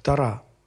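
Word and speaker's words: Тора: Евреи говорят правильно ударение ставить на последний слог. Тара, Пятикнижие Моисея.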